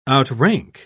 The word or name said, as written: outrank